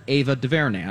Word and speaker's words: Ava DuVernay: But she threw a huge 3-day birthday celebration for her friend and director, Ava DuVernay.